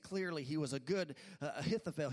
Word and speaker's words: Ahithophel: but his name is Ahithophel. Everyone say Ahithophel.